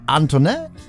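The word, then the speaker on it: Antoine: Unter anderem DJ Antoinette, so leicht französisch wird das ausgesprochen.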